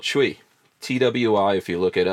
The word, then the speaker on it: Twi: Yeah, which is pronounced Chui.